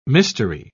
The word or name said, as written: mystery